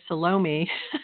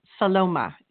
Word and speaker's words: Salome: And some people might say Salome, but it's Saloma is the correct way to pronounce that.